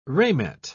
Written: ramet

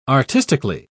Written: artistically

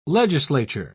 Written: legislature

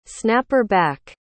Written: snapper-back

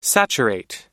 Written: saturate

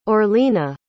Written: orlina

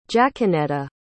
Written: jaquenetta

